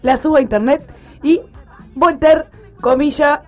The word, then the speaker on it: Voltaire: Y esta frase está atribuida a Voltaire, no sé si lo estoy pronunciando bien. – Voltaire, bien, muy bien.